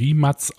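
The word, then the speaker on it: Rimac: Mate Riem, also der heißt Mate Riemack, vom, also vom, vom Sehner, aber wird jetzt anscheinend Rimatz ausgesprochen, ist ein Kroate mhm.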